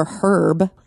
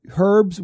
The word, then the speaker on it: herb: Herbs with an H to me sounded so utterly ridiculous, and I've been kind of like training myself to realize it's probably the better way to pronounce it. You know what I mean? – I don't like it. I don't think so. I think it's herb. Herb.